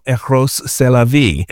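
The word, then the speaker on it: Rrose Sélavy: And Marcel Duchamp had a trans version of himself called Eros, c'est la vie, which, right, in French, that means you'd say Eros, that's life, right? But it's spelled R-R-O-S-E-S-E-L-A-V-Y, right? So the great pun, you know?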